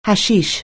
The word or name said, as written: hashish